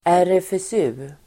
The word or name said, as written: RFSU